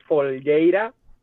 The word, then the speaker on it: Folgueira: My last name is Folgueira. It's kind of weird. It's not Brazilian. It's from Spain.